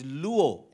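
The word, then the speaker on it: luo (λύω): And so the word destroy in John chapter 3, verse 8, is in the Greek, it is luo, L-U-O. For the benefit of anyone who's writing notes, it's spelled L-U-O, and it refers to the act of untying or unloosing something.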